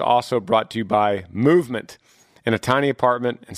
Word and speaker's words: MVMT: Movement, and that's spelled out MVMT, is how they call it, MVMT, grew into the fastest growing watch brand, shipping to over 160 companies across the globe.